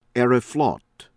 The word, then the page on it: Aeroflot: American English